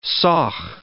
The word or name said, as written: saugh